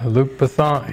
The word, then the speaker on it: Luc Besson: Has anybody ever heard of the filmmaker Luc Besson? Luc Besson? That's how you say it? Luc Besson? – Yes. – Yeah. Yeah, Luc Besson.